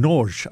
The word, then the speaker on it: Nagy: He's Andante, and the pianist is Peter Norge, and I'll tell you about N-A-G-Y pronunciation, Norge. I think that's correct. I used to know one of those people. I taught a couple of people N-A-G-Y, and they said that I can call them Nagy, but that's not the Hungarian way.